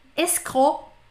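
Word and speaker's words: escroc: Escro, escro, que se escreve escroque. É, escro é tipo golpista, é golpista. Escro, não falo C também, mas escreve.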